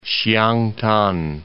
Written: Siangtan

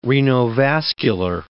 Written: renovascular

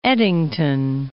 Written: Eddington

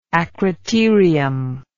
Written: acroterium

